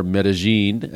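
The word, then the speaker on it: Medellín: who I interviewed down in Medellin, or Medellin,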